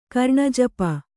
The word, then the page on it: ಕರ್ಣಜಪ: karṇajapa